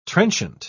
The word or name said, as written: trenchant